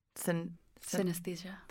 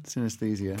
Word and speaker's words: synesthesia: Help me pronounce this. – Synesthesia. – Yeah, so... – That is a hard one. – Synesthesia.